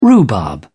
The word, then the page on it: rhubarb: British English